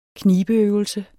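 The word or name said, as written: KNIBEØVELSER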